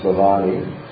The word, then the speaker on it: Bhavani: He's called here Bhavani, excuse me, not Bhavani. Bhavani, the Lord Shiva's one. You always mispronounce that name of his god. It's Bhavani.